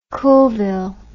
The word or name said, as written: coolville